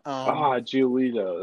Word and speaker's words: Giolito: Giolito. That's what it is. Giolito.